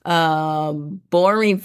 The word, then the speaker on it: Boreen: Boreen Family. It's not how it sounds like, it's how it's spelled.